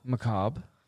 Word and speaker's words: Macabre: Macabre, yep.